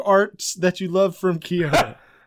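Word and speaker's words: Chiodo: I've heard it pronounced Kyoto. – Kyoto, okay. – Like the city in Japan.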